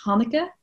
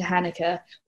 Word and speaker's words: Hanneke: And so in Dutch, it's pronounced Hanneke, but that's really hard to say. So we sort of anglicized it to Hanneke, or I go by Hank for short.